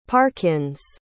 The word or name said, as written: parkins